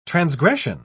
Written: transgression

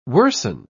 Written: worsen

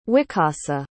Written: wicasa

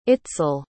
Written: itzel

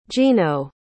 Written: gino

